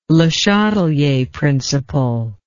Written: Le Chatelier principle

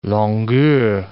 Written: longueur

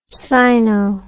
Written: sino

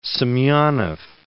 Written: Semenov

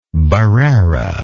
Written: barrera

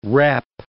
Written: repp

